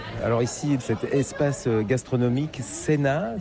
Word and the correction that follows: Seina: Seina. Ça s'écrit un peu comme la Seine, mais... – Voilà, avec un A à la fin, Seina. On prononce le I aussi. – Comme si on avait un tréma au-dessus.